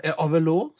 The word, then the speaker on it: Avelo: de uttaler det på fransk i USA. – Avelo. Avelo.